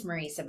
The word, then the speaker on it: Marisa: It's Marisa, but everybody does it.